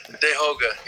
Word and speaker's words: Tehoka: Yeah, Dehoga, yeah. That's how you would say it. And, like, so my name is in Mohawk, and, like, so that's really how you would say my name.